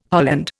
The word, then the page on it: holland: Indian